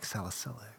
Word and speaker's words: salicylic: I just love to hear it pronounced the proper way. – Salicylic.